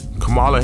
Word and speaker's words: Kamala: Camilla. What's that? How you saying that? Camilla? How you say it, kid? – Kamala Harris. – Kamala, my fault.